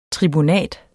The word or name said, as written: tribunat